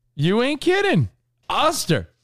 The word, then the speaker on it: Oster: You could get an air fryer for like $100 or so, like an Oyster one. Oster. – You call it oyster? – Well, it's spelled O-Y-S-T-E-R, right? – No. – What is it spelled? How's it spelled? – Oster. – O-S-T-E-R? – You put a Y in there.